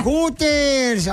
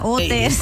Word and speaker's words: Hooters: Bueno, pues sí, porque la H es muda. Hooters.